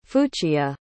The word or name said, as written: fuchia